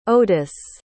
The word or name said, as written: odus